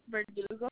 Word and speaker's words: Verdugo: S-A-R-A-I. – And last name? – Verdugo. – Can you spell that for me? – Yes, I'm Victor. E-R-D-U-G-O.